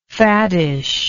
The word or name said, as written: faddishly